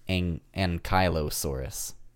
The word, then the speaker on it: Ankylosaurus: I always thought it was Ankylosaurus, but Gray says Ankylosaurus. – Mm. – Like Kylo Ren-saurus.